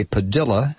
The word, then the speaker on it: Padilla: Well, I guess the template for this was the treatment of Jose Padilla, or Jose Padilla, as his family insisted his last name should be pronounced.